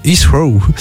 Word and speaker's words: Heathrow: Londres Heathrow.